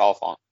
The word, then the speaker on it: Olyphant: Timothy, and I'm probably saying this wrong, Olyphant. – Yeah, Timothy Olyphant.